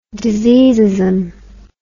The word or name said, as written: diseasism